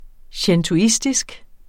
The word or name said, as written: shintoistisk